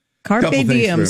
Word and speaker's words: carpe diem: Uh, and then it's uh, carpe diem. Is that how you say it? carpe diem. – Carpe diem.